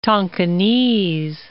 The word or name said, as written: Tonkinese